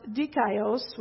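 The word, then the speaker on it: dikaios (δίκαιος): And this is pronounced dikaios, which is the Greek word here.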